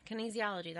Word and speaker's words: kinesiology: Did I say it wrong? Kinesiology. – Kinesiology. – Kinesiology, that's correct.